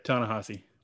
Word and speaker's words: Ta-Nehisi: And then now we're getting a new Superman, the Ta-Nehisi Coates, I'm sure I'm pronouncing that wrong. – Yeah, Ta-Nehisi.